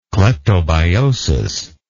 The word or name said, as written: cleptobiosis